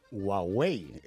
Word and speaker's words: Huawei: ¿Cómo se dice esto, hijo? – Pues me he enterado ahora con la polémica que es Huawei. – Ah, Huawei.